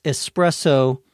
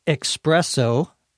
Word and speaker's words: espresso: These three words, espresso, which many Americans pronounce espresso as though there were an X in there, but there isn't.